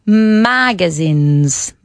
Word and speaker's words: magazines: Magazines. – Acentuamos la primera sílaba. Y la S es de estas que vibran.